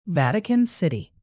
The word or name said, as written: vatican city